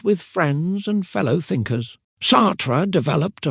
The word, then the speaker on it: Sartre: Jean-Paul Sartre, pronounced Sartre, was a French philosopher who lived from 1905 to 1980.